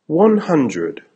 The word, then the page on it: one hundred: American English